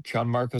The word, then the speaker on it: Gianmarco: It's at Gianmarco Cerezi, spelled with a G. It's Italian.